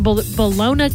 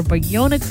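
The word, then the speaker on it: Ballona: It collects waste that's flowing through waterways, and it's already removed hundreds of tons of debris from Bayona Creek, or Bayona Creek, depending on how you pronounce it, near Marina Del Rey. Actually looked it up, and I guess you can pronounce it either way.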